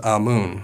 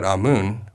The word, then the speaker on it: Amun: Research suggests that Amun, or Aman, is not likely pronounced the same as Amen.